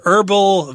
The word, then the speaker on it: herbal: Yeah, Americans tend to not pronounce the H there. Herbal vanilla tea, if you drink it, it can kind of take care of your sore throat.